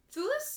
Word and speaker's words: Thewlis: Correct me if I say his last name wrong. David Thewlis? Thewlis? – Yes. Yeah. That sounds right.